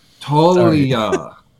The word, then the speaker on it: Toglia: The G is silent, my friend. Talia.